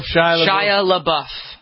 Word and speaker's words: Shia LaBeouf: Shia LaBeouf? – Shia LaBeouf.